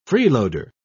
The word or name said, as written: freeloader